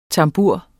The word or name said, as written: tambur